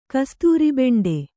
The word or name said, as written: ಕಸ್ತೂರಿಬೆಂಡೆ